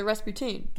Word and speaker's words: Rasputin: So Rasputin? – Yeah. – Like poutine, like the food. Ras. What? – Yeah. – Poutine. – Yeah. Yeah. That's how it's pronounced.